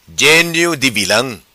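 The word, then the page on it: Vilam Genio Di: patua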